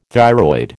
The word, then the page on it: thyroid: American English